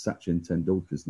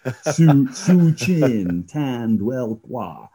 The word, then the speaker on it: Sachin Tendulkar: And it's in a new stadium, 100,000 seaters, where Donald Trump tried to pronounce Sachin Tendulkar's name and got it absolutely mangled. Su-chin, tanned, well, qua.